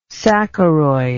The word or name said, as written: saccharoid